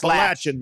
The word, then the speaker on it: Appalachian: Now they're saying it's Appalachian. I thought it was Appalachian. – It's Appalachian, man. – It's Latch. – It's Latch. – Lock it in your mind.